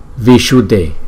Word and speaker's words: Vishuddhe: Vishuddhe, Vishuddhe, Vishuddhe, Vishuddhe, Vishuddhe, Vishuddhe, Vishuddhe. Chữ S là cong lưỡi, Vishuddhe, D, D. Chữ D với chữ H với chữ E là đọc là D, D, D.